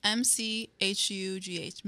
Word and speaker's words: McHugh: McHugh, I think. I think you're right, McHugh, because it's M C H U G H McHugh. McHugh. McHugh.